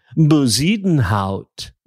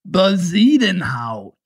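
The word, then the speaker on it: Bezuidenhout: That would be Christian Buzidenhout. – Buzidenhout. – Yes, yes. And you know what? The announcers on television are finally starting to get with the program and pronunciate the name correctly. Buzidenhout.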